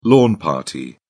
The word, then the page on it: lawn party: British English